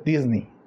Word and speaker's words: Disney: Y yo ya había escuchado mucho que no se dice Disney, se dice Disney. Disney. Disney. Disney. Ajá, sí la E. Eh, la pronunciación correcta es Disney.